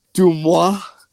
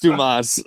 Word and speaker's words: Dumas: That's because Billy was, when Joe Dumas was on, he's like, am I pronouncing this right? Is it Dumois? And he's like, no, it's Dumas.